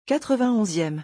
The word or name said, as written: 91eme